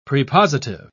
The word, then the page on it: prepositive: British English